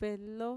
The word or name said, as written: velo